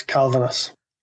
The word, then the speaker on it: Kalvenes: The right one is Kalvenes.